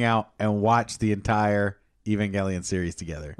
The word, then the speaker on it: Evangelion: It's a hard G. – It's a okay. hard G. I'm sorry. – Okay, fine. – I know. – Evangelion.